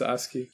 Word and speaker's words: ASCII: Yeah, it's ASCII.